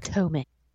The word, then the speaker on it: Potomac: Potomac.